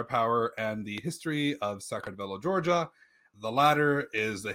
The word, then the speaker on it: Saqartvelo: From the history of Sacramento, Georgia. I don't know how to say that. – You said it right.